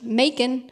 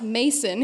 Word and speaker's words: Macon: I recorded the audiobook, and the people at Penguin Random House wrote back to me on a couple things and said, hey, you mispronounced Macon. And I was, it's supposed to sound like bacon. And I said, oh, no, let me introduce you to a few country songs off the internet. It's pronounced Mason here.